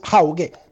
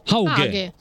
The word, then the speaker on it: Hauge: Il nuovo giocatore del Milan, norvegese, il suo cognome si pronuncia Hauge. Ciao. – Hauge.